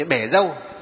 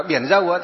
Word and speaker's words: bể dâu: À, ví dụ như bể dâu, thì đúng là biển dâu, nhưng mà nếu mà đọc là biển dâu thì nó gì? Nghe nó không hay mà nó không chiều tượng, cho nên các cụ đọc là bể dâu.